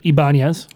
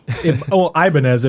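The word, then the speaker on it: Ibáñez: Ibanez. – Ibanez, if you watch this movie, they mispronounce it for the entirety of the film.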